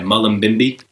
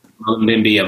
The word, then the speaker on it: Mullumbimby: I'd hoped to visit, I hope I'm pronouncing this right, Mullumbimby. – Correct, yes. – Mullumbimby, I was supposed to be there in May of this year, 2020, but hopefully I can come back another time.